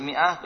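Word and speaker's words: mi'ah (مائة): Kalau nulis mi'ah, begitu nulisnya. Mim terus alif. Nah, mi'ah, jami'an? – Mi'ah. – Nah, jangan sampai mi'ah, bingung bacanya ini. Bacanya mi'ah. Nah.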